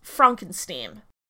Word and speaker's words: Frankenstein: And, to dissociate himself from his ancestor, Frederick insists that his surname is pronounced Frankenstein. When